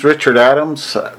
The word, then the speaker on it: Richard Adams: My name is Richard Adams. That's R-I-C-H-A-R-D-A-D-A-M-S. – Just as it sounds. – Yeah, right. Not too hard.